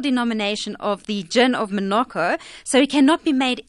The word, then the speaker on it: Menorca: is it the Menorca gin? Am I pronouncing it correctly? – Yes, you are.